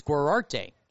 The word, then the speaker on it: Guarate: Gorarte is spelled G-U-A-R-A-T-E. It's spelled like karate, but it's Gorarte, so a little bit similar.